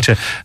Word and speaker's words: Tuilte: And again, you'll be watching TUILTE, isn't that how I pronounce it? – TUILTE.